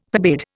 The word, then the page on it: forbade: Indian